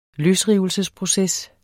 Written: løsrivelsesproces

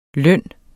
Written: løn

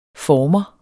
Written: former

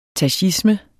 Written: tachisme

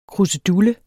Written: krusedulle

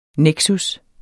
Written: NEKSUS

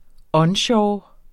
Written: ONSHORE

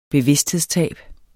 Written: bevidsthedstab

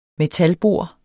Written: metalbor

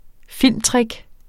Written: filmtrick

